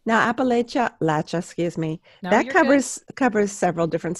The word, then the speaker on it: Appalachia: So the correct pronunciation is what? – Appalachia.